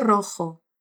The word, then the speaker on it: rojo: A primeira sílaba é RO, é RO. Esse R do início da palavra é bem vibrante. Tenta. RO. E a segunda sílaba é com J, J-O, ou seja, rasga a garganta. RO. Vai. RO. RO jo, rojo, abrigo rojo. Assim é.